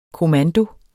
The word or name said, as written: kommando